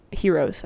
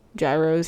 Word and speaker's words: gyros: I love Heroes, I think is how they pronounce it, I think. Gyros, heroes. – Gyros. You said heroes? – No, they call them heroes.